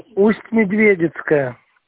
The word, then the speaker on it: Усть-Медведицкая: Подскажите, пожалуйста, это Медведицкая, правильно попала? – Усть-Медведицкая. – Извините, пожалуйста, за ударение, потому что хотела вас уточнить.